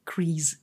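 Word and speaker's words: Kryze: Ja, also es wird, es wird in den Serien immer als Grease gesprochen.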